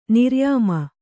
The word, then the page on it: ನಿರ್ಯಾಮ: Kannada